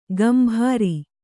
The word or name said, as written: ಗಂಭಾರಿ